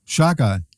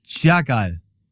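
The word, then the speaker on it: Chagall: Schagal. – Schagal.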